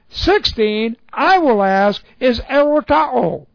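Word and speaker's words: Erotao (ἐρωτάω): A future active indicative of the verb erotao. Erotao. This is E-R, long O, T-A, long O.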